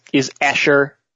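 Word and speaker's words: Escher (אשרי): The word is Escher. Sounds like like the artist M.C. Escher. It's basically pronounced the same way.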